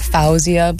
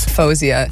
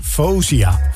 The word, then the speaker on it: Fauzia: Hoe spreek je dit uit? Fauzia? – The worst that happens is like, I think like Fauzia.